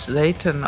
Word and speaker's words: Seitan: Es wird auf Englisch Seitan ausgesprochen und das bietet sich doch voll an, als Black-Metal-Typ dann das dem Satan zu huldigen.